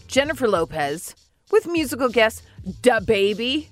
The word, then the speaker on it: DaBaby: DaBaby. – It was pretty good. – I mean, she put a lot less emphasis on duh.